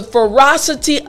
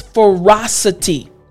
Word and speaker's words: ferocity: Now, let me break down the pronunciation of this word. You understand that it means savage cruelty, but the word again is ferocity. Ferocity. Now, it's not really difficult to pronounce this word, but I just want to go over the last portion of the word. City. City. Yes, you got it.